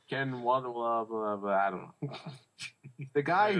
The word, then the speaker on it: Ken Watanabe: Ken Watanabe.